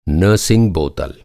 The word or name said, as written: नर्सिंग बोतल